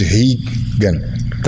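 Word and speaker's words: Rigan: I'm calling you Regan, but the real pronunciation is Hegan, right? – Hegan Machado.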